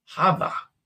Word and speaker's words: Jova: If Jova is a Spanish name, then it's pronounced Hava. Hava. I said Hova. Okay. I knew the J was pronounced like an H, but I did not put it into Hova.